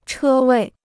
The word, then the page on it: 车位: chē wèi